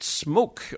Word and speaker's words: Smok: Project Smok, or S M O K is how you pronounce or you know, if you're typing it out, they just left out the E for some reason.